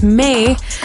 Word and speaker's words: Maed: My name is May, and it's spelled M A E D.